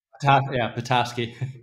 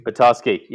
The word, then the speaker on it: Petoskey: Petoskey. – Petoskey.